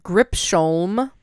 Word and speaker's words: Gripsholm: The Lion of Gripsholm. So spelled like Grip, S-H-O-L-M, Gripsholm Castle.